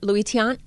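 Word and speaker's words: Luis Tiant: And remember Red Sox Hall of Famer Louis Tiant. Did I say that right? – Mm-hmm. Yeah. Oh, yeah. You nailed that.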